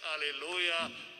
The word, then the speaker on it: Alleluia: It's four syllables, not a stervy English two one. So focus on that when we come to that. Alleluia, Christ is risen.